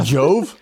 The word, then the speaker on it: Jove: Joba or Jova? – Jove. – How do you spell it? – J-O-V-E.